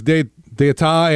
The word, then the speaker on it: D'Etat: Rolling tonight uptown, the crew of Hermes, D'Etat, and Morpheus. Did I just butcher that, Jeff? – No, you got it.